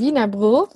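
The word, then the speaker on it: Wienerbrød: Also ein, ein Wiener Brot wird das ausgesprochen. Ja. Das ist so dieses äh, dänische D, was so irgendwie mit der heißen Kartoffel im Mund gesprochen wird.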